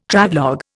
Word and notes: Travelogue: Indian

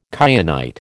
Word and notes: kyanite: American English

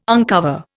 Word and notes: uncover: Indian

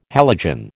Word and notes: halogen: American English

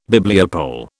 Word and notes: Bibliopole: British English

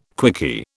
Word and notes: quickie: British English

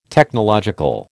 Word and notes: Technological: American English

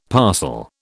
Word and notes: parcel: British English

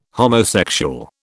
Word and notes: homosexual: British English

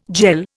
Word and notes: jell: Indian

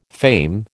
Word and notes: fame: American English

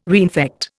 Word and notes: reinfect: Indian

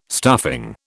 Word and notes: stuffing: British English

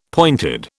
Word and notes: pointed: British English

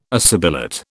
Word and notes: assibilate: British English

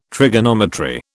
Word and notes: trigonometry: British English

